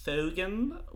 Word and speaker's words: Phogan: My Twitter handle is Thogan, which is spelled P-H-O-G-A-N. – I'm glad that you actually say it that way. Do you always say it that way to people? I'm Thogan.